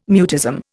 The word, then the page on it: mutism: Indian